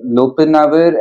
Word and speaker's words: lopinavir: That is uh, lopinavir and ritonavir.